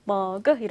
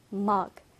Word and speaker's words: mug: 자, 그런데 우리말로는 몇 음절이에요? 머그. 그렇죠. 머그 이렇게 이음절인데 영어 발음은 mug, mug이죠.